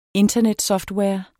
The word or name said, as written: internetsoftware